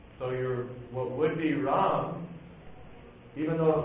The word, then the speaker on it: Ram: In Hindi, this is a one syllable word. Ram Mandir. Ram. A one syllable word. In Sanskrit, two syllables. Ram, Ram, Ram, Eti. – Ram, Ram, Ram, Eti. – Another verse, Ram, Ram, Ram, Eti. Satranam, Satyam, Ram, Ram, Ram, Eti. – So it's a two-syllable word.